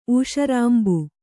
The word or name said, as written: ಊಷರಾಂಬು